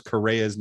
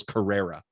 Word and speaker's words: Correa: And I'll never forget sitting at games and hearing people pronounce Carlos Correa's name, Carlos Carrera. And I'm like, I just wanted to like strangle people.